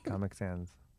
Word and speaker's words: Comic Sans: So last night, is that how you say it? – What? – Comic Sans. – Sans. Okay. Comic Sans.